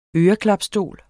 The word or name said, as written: øreklapstol